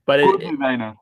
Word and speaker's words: Corduwener: Corduwener.